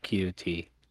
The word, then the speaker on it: QT: QT. Like QD.